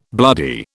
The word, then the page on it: bloody: British English